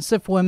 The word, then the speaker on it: SFWA: Dear Nebula Conference participants and CIFWA members, that's how it's pronounced. SFWA is pronounced. CIFWA is how they do it, is how they say it.